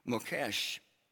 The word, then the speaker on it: Mokesh: M-O-K-E-S is the way it's rendered in English, but pronounced Mokesh.